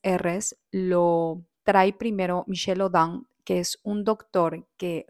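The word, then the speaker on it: Michel Odent: Y tiene muchos libros, búsquenlo, Michel Audin, en español se escribe Audin, con T al final, tal como lo escuchas, Audin.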